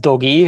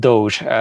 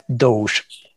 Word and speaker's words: Doge: Do- dogének írják, de nem, nem Dogi, hanem Doge a hivatalos kiejtése.